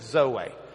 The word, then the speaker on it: zoe: but he uses the word zoe. Zoe. Zeta, Omicron, Eta. That A sound looks like our N with a tail. But it's a long E, in a sense. It's the eta. So, zoe.